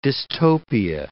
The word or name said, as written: dystopia